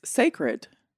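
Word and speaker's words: Cécred: Because in the caption it said hair is sacred, S-A-C-R-E-D. – Okay. – The new hairline is spelled C-E-C-R-E-D. – Right. That's sacred. – So think about it. So Beyonce, the end of Beyonce is C-E. – Oh. – So it's sacred.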